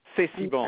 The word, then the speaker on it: C'est si bon: C'est si bon.